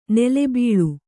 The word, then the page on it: ನೀಲಿಬಳ್ಳಿ: Kannada